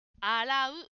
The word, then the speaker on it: 洗う: あをもう少ししっかり発音しましょう。洗う、洗うです。